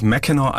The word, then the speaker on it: Mackinac: Die nennt sich Mackinac Island und schreibt sich mit CK, Mackinac. Hat also französische Ursprünge mal gehabt und es gibt tatsächlich viele Orte in Michigan, die ganz anders ausgesprochen werden, als sie sich schreiben.